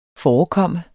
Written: forekomme

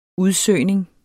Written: udsøgning

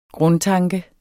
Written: grundtanke

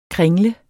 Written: kringle

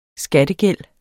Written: skattegæld

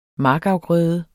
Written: markafgrøde

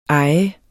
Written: ejer

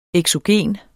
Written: eksogen

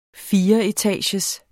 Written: fireetagers

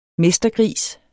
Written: mestergris

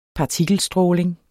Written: partikelstråling

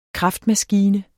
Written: KRAFTMASKINE

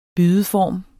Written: bydeform

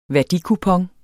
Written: værdikupon

